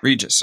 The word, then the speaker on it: Regus: Regus.